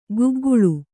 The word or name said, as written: ಕಿಗ್ಗಳ